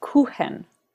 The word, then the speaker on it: Kuchen: See, it's spelt Kuchen. It's pronounced Kuchen.